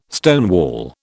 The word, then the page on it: stonewall: British English